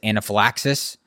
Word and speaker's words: anaphylaxis: anaphylaxis. Did I say that right? – Yeah. – Okay. – Crushed it.